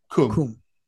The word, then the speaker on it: Qom: How do you say that? Qom, the religious capital of Iraq. – Qom. – Qom. It's spelled Qum, right? – Or Qom, depending on which transliteration you want to use.